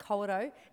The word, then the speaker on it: Kawerau: but Kawerau is how you say it.